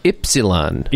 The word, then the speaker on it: Ypsilon: Yeah, well, in German, it's Ypsilon. – Ypsilon? – So Ypsilon, that's how you say what? – So it's like four syllables.